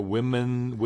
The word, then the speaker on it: WIMXN: The garden party for HRC and then the Equality California Women. How do you say that? WIMXN? – Women.